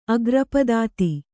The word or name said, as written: ಅಗ್ರಪದಾತಿ